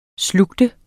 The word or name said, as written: slugt